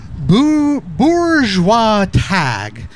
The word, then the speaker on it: Bourgeois Tag: And that was, I have to read it from the cover here because they give you a handy dandy pronunciation guide, Bourgeois Tag. That's Bourgeois Tag.